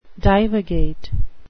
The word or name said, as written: divagate